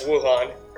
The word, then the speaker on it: Wuhan: I believe I'm saying that correctly. – Yes, Wuhan.